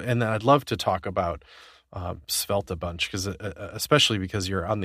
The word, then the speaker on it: Svelte: So, Rich is, you know, one of the big projects you work on is called Svelte. And I've confirmed with Rich that I pronounced it correctly, too. It's not Svelte or Svelte or any kind of weird anything.